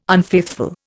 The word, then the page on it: unfaithful: Indian